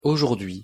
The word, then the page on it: aujourdhui: French